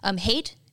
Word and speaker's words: Haidt: Haidt? It sounds like hate, which is really unfortunate, but it's spelled Haidt.